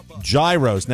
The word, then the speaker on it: gyros: gyros. Now, I pronounce it gyros. We used to work with a radio personality who said gyro. And I suppose that is the proper Greek pronunciation.